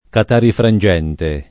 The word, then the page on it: catarifrangente: Italian